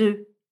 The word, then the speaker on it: œufs: On prononce donc œuf. Attention, le pluriel d'un œuf est des œufs. Et là, le son E dans l'eau se prononce avec un son de E fermé.